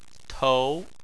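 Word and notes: tou: tone 2

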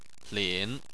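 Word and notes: lin: tone 3

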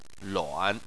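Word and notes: luan: tone 3

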